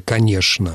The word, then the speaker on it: конечно: То есть остается несколько слов, которые получили общелитературное произношение через «ш», вроде «конечно».